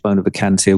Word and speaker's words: Bona Vacantia: It is a mystery to many people still, including the pronunciation of Bona Vacantia, which you handled very professionally.